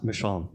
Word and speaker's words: Michonne: Michonne, ja.